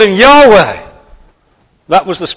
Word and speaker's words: Yahweh: The name nowadays is usually pronounced Yahweh. It used to be pronounced Jehovah. Nobody actually knows how it was pronounced because we only have the vowels, Y-H-W-H.